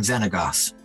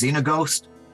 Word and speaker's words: Xenagos: I am Xenagos. Sometimes mispronounced as Xenaghost, but, you know, you'll think that you've Xenaghost later on in the day.